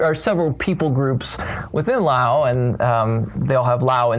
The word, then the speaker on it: Lao: But the first one is that I, as far as I can tell, it's pronounced Lao, like the name of the country. They don't say the S, or when people in the country say the S, they're just emphasizing it for foreigners so they can understand that.